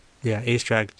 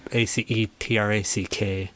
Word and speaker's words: Oesterreich: So the way, Aestrack, just Aetrack.